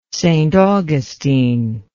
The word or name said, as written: st augustine